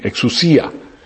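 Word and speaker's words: exusia (ἐξουσία): Exusías es la palabra, está teniendo el acento en la I, exusia.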